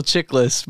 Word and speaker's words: Chiklis: No, it's Michael Chiklis, but it's better when you say Chiklis, because I'm like, oh, yes, it's Chiklis.